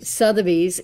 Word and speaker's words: Sotheby's: Prior to viewing homes with your Cooper Sutherly, am I saying that right? – Sotheby's International Realty Agent, it's important to clarify, these are three big things that I would have conversations with you about.